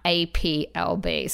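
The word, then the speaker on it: A'pieu: it's no longer Appleby. We're going to go with the English, like, um, pronunciation. So it will be now APLB.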